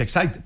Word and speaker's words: Exide: se escribe Exide, se pronuncia Exide, dentro decía el comercial de las baterías.